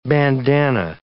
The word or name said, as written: bandanna